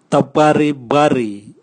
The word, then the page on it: tabwaribwari: Raga